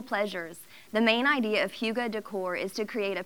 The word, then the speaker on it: hygge: There's a Danish word spelled H-Y-G-G-E, but pronounced hygge.